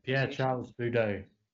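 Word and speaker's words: Pierre-Charles Boudot: Mad at the moment in the French, I think I get the pronunciation. Is it PC Boudet? Is that correct? – Yeah, Pierre Charles Boudet.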